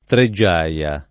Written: Treggiaia

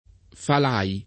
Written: Falai

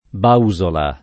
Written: Bausola

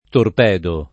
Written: torpedo

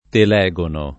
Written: Telegono

